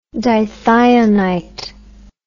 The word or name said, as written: dithionite